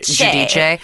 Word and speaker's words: Giudice: It is actually in Italian pronounced Giudice.